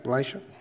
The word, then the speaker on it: Elisha: Elisha is the right pronunciation? Yeah, Elisha.